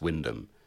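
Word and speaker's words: Wymondham: Wyndham. – Defund the BBC, hashtag. – Which is spelled Wimmendom. – But oh, really? – It's Wyndham. And if you say it wrong, it'll be hell to pay.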